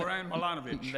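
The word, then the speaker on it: Zoran Milanović: Actually, uh, was it Zoran? – Zoran Milanovic. There we go.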